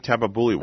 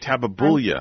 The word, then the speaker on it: tabebuia: Linda, you also have the pink tabebuia. Am I saying that correctly? – Yes, you are. – Pink tabebuia. Wow, well, big boy word. I figured it out. – You nailed it. – Pink tabebuia.